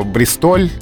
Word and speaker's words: Бристоль: Словари действительно фиксируют Бристоль или Бристоль, но вот в словаре имен собственных Агейнка Бристоль дается как предпочтительный, как рекомендуемый для эфира. И все-таки странно. Город английский, а ударение на французский манер.